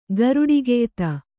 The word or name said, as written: ಗರುಡಿಗೆಯ್ತ